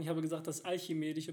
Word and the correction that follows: archimedische: Prinzip, aber es ist das archimedische Prinzip.